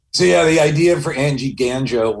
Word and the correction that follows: Gango: was Angie Gango. I think he was Gango. I love that I'm a stickler for the pronunciation of a ridiculous fictional character that only 20 people heard.